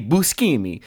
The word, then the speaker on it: Buscemi: Sorry, I got to do the correct Italian pronunciation. I don't know if Steve Buscemi is in Trolls, but I do know that Trolls is him.